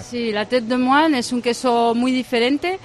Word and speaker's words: Tête de Moine: Tête de Moine. – Sí. – A ver, repítelo, ¿cómo es? – Sí, Tête de Moine. – Tête de Moine, sí.